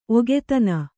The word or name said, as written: ಒಗತನ